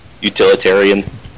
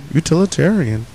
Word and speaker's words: utilitarian: And probably very, like utilitary? Did I say that right? – No. – Damn it. Utilization? – Utilitarian. – Bam! That's what I said. That's what I said the first time. Utilitarian.